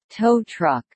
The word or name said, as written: tow truck